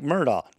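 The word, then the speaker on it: Murdaugh: The last name is Murdoch, pronounced like Murdoch, not Murdoch. The G-H is silent and no K sound.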